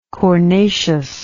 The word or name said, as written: cornaceous